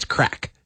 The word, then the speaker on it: craic: There's a, it's spelled differently, but it's crack. It's spelled C-R-A-I-C.